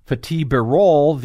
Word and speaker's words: Fatih Birol: Fatih Birol, the agency's executive director, did I say it right? – Yes. – Close enough. – No, that was right.